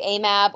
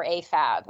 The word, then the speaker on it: AMAB: Typically you say AMAB or AFAB, and those are acronyms that mean assigned female at birth or assigned male at birth.